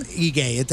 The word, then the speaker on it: Ige: Ige. That's how you say it.